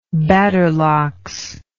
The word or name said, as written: badderlocks